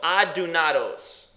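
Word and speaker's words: adunados (ἀδύνατος): It's the word udinados. No, adunados. That's what it says, adunados.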